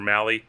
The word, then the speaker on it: Mahle: Tyler Mahle, in 6 innings, or Mahle, keep saying his name wrong.